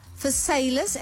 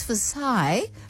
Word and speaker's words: Versailles: And when I was born in Wellington, 21, Versailles, and I didn't know until I went to France to go and get an award there, that they said, it's Versailles. That I realized we pronounce it incorrectly.